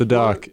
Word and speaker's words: Sadok: My name is Sadok. What? It rhymes with flock.